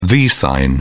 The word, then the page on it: v sign: British English